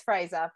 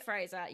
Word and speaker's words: Fraser: I think it's Fraser, Brendan Fraser. – We're just going to say Fraser, yes.